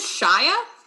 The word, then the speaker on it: Chaya: Mine is Shia. – That's pretty cool. – It's spelled very strangely and is a little bit made up. It's spelled C-H-A-Y-A. – Yeah. – So it looks like Chaya.